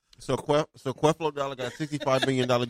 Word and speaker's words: Creflo: Creflo.